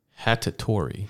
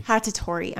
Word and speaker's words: Hattori: Hattori. – Hattori.